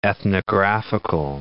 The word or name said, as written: ethnographical